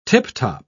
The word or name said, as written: tiptop